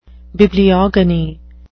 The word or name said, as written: bibliogony